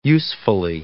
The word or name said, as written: usefully